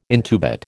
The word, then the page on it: intubate: American English